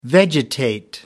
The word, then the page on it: vegetate: American English